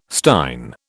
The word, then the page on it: stein: British English